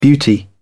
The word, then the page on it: beauty: British English